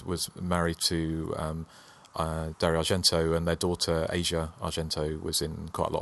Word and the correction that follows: Asia: I think it's pronounced Arzia. – Arzia, sorry. I think they were just about to break up, apparently, during the filming.